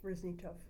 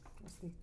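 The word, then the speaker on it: Rozniatow: R-O-Z-N-I-A-T-O-W. R-O-Z-N-I-A-T-O-W. – Okay, and you say that? – Roznitsov. – Roznitsov.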